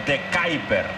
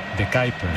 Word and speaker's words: De Kuiper: Entonces sabe cómo se pronuncia. Y ha dicho algo así como de Kuiper. – De Kuiper.